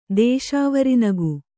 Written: ದೇಶಾವರಿನಗು

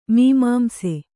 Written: ಮೀಮಾಂಸ

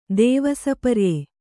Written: ದೇವಸಪರ್ಯೆ

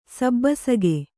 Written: ಸಬ್ಬಸಗೆ

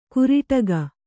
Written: ಕುರಟಿಗೆ

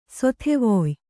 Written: ಸಾಧವ್ಯ

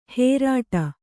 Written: ಹೇರಾಟ

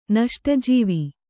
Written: ನಷ್ಟಜೀವಿ

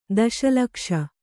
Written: ದಶಲಕ್ಷ

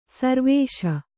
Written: ಸರ್ವೇಶ

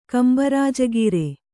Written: ಕಂಬರಾಜಗೀರೆ

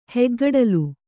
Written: ಹೆಗ್ಗಡಲು

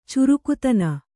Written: ಚುರುಕುತನ

